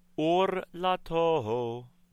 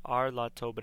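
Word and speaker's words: orlato: Isha ki tazria v'yalda zachar v'tam'a shiv'at yamim ki mei nidat dotah titmah uvayom hashmini yimol Bisar or lato. – The word looks like ar lato, but it is pronounced or lato.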